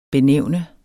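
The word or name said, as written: BENÆVNET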